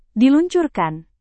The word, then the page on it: diluncurkan: Indonesian